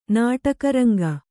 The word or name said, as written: ನಾಟಕರಂಗ